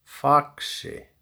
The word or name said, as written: Faxi